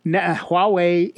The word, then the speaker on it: Huawei: Google is planning hybrid devices that run both Android and Chrome OS, including one convertible laptop and one Huawei HUAWEI, I believe is pronounced Huawei, Nexus-branded tablet, according to current rumors.